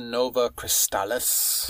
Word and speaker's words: Nova Crystallis: I can never pronounce it without reading it. Novellus Crystallis. – Fabula, like, Nova Crystallis. – Thank you very much.